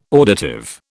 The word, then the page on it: auditive: British English